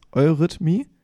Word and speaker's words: Eurythmie: Eurythmie. Eurythmie. Eurythmie. Eurythmie. Eurythmie. Eurythmie. Eurythmie. So ja. wie euer Rhythmus. Eurythmie.